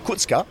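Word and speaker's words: Kucka: When Tufan came on, it meant Sissoko and Kutska, as I've been told by Slovakian, is how you say his name, Kutska, they dropped a bit deeper.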